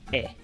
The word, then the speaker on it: he: So H-E or pronounced E.